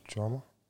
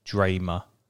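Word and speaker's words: Drameh: Drama? – Yeah, but it's pronounced like Dramer.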